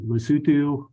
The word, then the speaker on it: Lesotho: But also another one that stood out was the Lesotho, which I was always calling it Lesotho because that's how it's spelled, but Lesotho, which it's kind of weird,